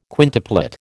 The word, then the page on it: quintuplet: American English